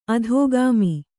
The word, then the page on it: ಅಧೋಗಾಮಿ: adhōgāmi